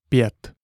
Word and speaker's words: pět: IE is pronounced like the Y plus E in English. Yes, with a small hook above it. For example, pět, meaning five.